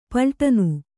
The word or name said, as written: ಪಲ್ಟನು